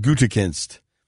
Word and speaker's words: Gutekunst: and then Paul Gutenkist. I do a very poor job of pronouncing his name. And let me clarify, his first name is Brian, not Paul. Brian Gutekunst.